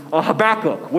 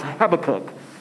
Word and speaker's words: Habakkuk: I think specifically about Habakkuk, which I've heard the old saints pronounce Habakkuk. So however you pronounce it, whatever church community you come from, Habakkuk or Habakkuk, there was one particular time where he was facing opposition.